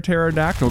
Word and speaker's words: pterodactyl: Why can't you hear a pterodactyl going to the bathroom? – Because the pee is silent.